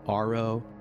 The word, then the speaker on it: AARO: The UAPTF quietly evolved into what we now have, which is the AARO, or the All Domain Anomaly Resolution Office, pronounced ARO, which is at least easier to say.